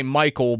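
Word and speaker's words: Mycole: Yeah, it's M-Y-C-O-L-E. So, like, I want to say Michael, but you almost have to, like, hesitate there and say Michael.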